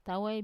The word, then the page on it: tawai: Wamesa